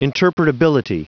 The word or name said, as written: interpretability